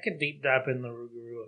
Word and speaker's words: Rougarou: Rougarou. – That's how you say it.